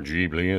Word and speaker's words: Ghibli: There's bits of Chitty Chitty Bang Bang and bits of When Harry Met Sally and loads of Ghibli, actually, or Ghibli, as you would pronounce it.